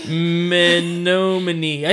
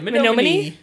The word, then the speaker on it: Menominee: Menominee. I said it right. – Menominee.